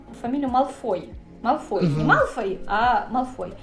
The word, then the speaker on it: Малфой: Ну, для меня резануло слух просто потому, что я привыкла к Малфой как в английском, так и в русском. И я когда читаю, я тоже всегда читаю с ударением Малфой.